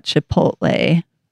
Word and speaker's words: Chipotle: Let's switch over to the food area, and I wanted to talk about Chipotle. Am I saying that right? I got yelled at for saying it wrong. – Correct.